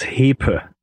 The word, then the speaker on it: Hepe: It's Hepa. – Hepa. – Yeah. – Okay, H-E-P-E.